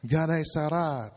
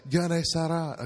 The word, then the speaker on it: Gennesaret: I had no idea how to pronounce this. So go into my Bible software, click the little automatic voice, you know, the little Gennesaret. Gennesaret. So there we go. Now we can all say Gennesaret.